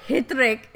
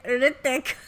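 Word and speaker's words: Hrithik: It's not Hitrik, it's Hritik. – I thought he had an H in his name. – It's Hritik. – But it's silent. It's a silent H.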